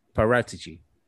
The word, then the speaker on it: Paratici: It's Paratici or Paratici. It's not as confusing as we thought it was. – It's supposed to be Paratici.